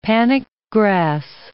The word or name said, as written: panic grass